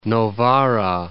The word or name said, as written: Novara